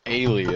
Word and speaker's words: Ailee: Is it Ailey or Ailey? – Ailey, as far as I know.